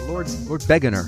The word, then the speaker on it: Lord Beginner: How did he pronounce it? Lord Beginner. That's the way he pronounced it. I'll go with Beginner. That's how it's spelled.